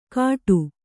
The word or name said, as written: ಕಾಟು